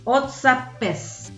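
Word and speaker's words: O'zapft is: pues que en alemán más o menos se pronuncia así, Otzapes, que significa abierto.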